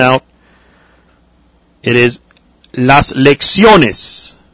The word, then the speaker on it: lecciones: Now, lecciones does end in S, but the stress is on the O. It's on the next to the last syllable.